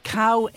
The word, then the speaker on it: Khao: And please note that cow is spelled K-H-A-O.